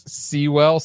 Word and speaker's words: Sewell: With the stars, with the lead stars being Heath Ledger, Mark Addy, Rufus Sewell, Sewell, well, Sewell, maybe. Sure. The music was composed by Carter Burwell, which rhymes with Sewell.